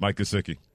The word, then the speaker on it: Gesicki: Mike Gesicki.